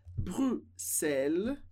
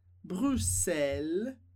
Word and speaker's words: Bruxelles: Que tem duas maneiras corretas de pronunciar essa palavra, de pronunciar o X. Porque a gente pode falar Bruxelles, como se fosse dois S, Bruxelles, Bruxelles, mas também a gente pode pronunciar com o som típico do X em francês, que seria X, X.